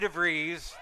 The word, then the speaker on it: DeVries: With the help of trusty treasurer Vicky DeVries, rhymes with cheese,